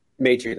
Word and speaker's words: Maitri: Maitri, that's right.